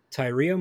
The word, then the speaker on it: Tyria: Tyria Moore. Yes. – Okay. – It's T-Y-R-I-A.